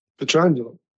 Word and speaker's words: Pietrangelo: Alltså mitt uttal där. Pietrangelo. Kan du hjälpa mig här? – Pietrangelo. – Alltså Pietrangelo. – Ja. – Lät okej. – Jag snubblade på Angelo.